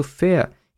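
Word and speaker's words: ver: fea. Né? Fea é porque a gente está fazendo a pronúncia correta do alemão, porque na verdade é V-E-R. Né? E o V no alemão tem um som de F.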